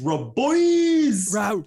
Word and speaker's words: Rabois: How do you pronounce this guy's name? Is it Keith Raboy? – It's Raboys.